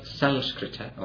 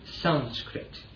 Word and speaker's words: Sanskrit: Now this word sanskrita or asanskrita, conditioned or unconditioned, is the same word, interestingly enough, as the name of the language Sanskrit. Sanskrit is the anglicized way of pronouncing it. It should be Sanskrita.